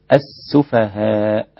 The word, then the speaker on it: السفهاء: سيقول السفهاء من الناس، السفهاء أيضا السين رقيقة، واللام قبلها لام شمسية تكتب ولا تنطق السفهاء هكذا وليس السفهاء.